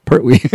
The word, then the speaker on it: Pertwee: It stars Christopher Lee, Peter Cushing, Nyree Dawn Porter, Denholm Elliott, and John Pertwee? How do you say that? – Pertwee. Yeah, Pertwee.